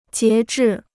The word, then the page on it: 截至: jié zhì